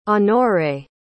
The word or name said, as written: honore